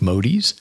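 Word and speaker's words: Modys: Sometimes it's nice to have a unique last name, like Modies. That's M-O-D-Y-S.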